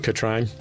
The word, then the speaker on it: Katrin: Katrina writes, Katrin? Katrin. K-A-T-R-I-N. That's a weird name. – It's pronounced Katrine.